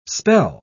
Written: spell

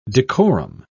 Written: decorum

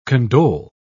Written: condole

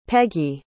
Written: Peggie

